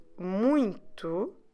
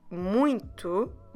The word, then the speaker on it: muito: M-U-I-T-O. And I know, and we've talked about this before in, on other episodes, but the word muito, it is quite nasal in the beginning, although this time, for once, the spelling doesn't let us know about it. So just remember to use a bit your nose as well in the beginning of the word for the first syllable and try to pronounce it a bit nasal. Muy, muy, muito, muito.